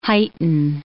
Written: heighten

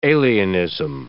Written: alienism

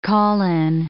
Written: call-in

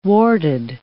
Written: warted

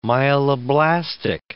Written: myeloblastic

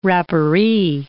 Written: rapparee

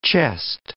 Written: chest

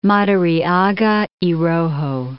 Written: Madariaga y Rojo

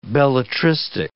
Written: belletristic

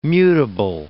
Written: mutable

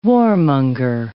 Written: warmonger

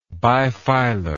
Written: bifilarly

